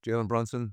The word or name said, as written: Jalen Brunson